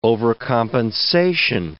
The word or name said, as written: overcompensation